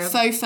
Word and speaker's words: Faux: Faux fur. Faux fur. A little, little bit of Francais. Yeah, Full, the X yeah. is not pronounced. Silent.